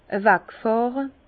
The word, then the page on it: awag fàhre: Bas-Rhin; Herrlisheim